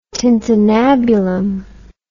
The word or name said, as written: tintinnabulum